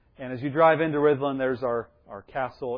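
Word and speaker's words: Rhuddlan: So in Welsh, the double D is pronounced like a TH. And while we speak English there, all the road signs are in English and in Welsh. So that's pronounced Rhyddlan.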